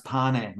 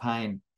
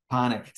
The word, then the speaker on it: Pane: Just so you know, Jez, by the way, my surname is pronounced Pane, not Pain. Pane. So it's no big deal. Pane is actually an Italian name. It means bread.